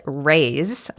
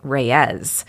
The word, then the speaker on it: Reyes: Yeah, it's interesting because apparently the locals call it Reyes, one syllable, Point Reyes. But then when I was watching some YouTube videos that the Park Service had made, the rangers on the videos were pronouncing it Point Reyes, two syllables.